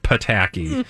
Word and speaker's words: Pataky: Emphasis on the tacky. – It is Pataky. Because if you the P is silent.